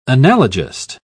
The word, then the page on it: analogist: British English